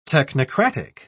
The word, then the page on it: technocratic: British English